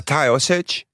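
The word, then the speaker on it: Taoiseach: Tayo Sech. Tayo Sech. – Hoe spreek je dat echt zo uit? – Ja, Tayo Sech.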